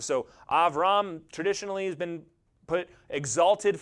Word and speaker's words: Abram: His name would have been pronounced Avram.